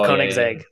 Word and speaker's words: Koenigsegg: Uh, I don't even know how to say this, but like Kinosag. Oh, oh, the Koenigsegg, the Koenigsegg, yeah, Koenigsegg, the CCX.